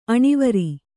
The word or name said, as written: ಅಣಿವರಿ